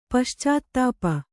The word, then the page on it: ಪಶ್ಚಾತ್ತಾಪ: kannada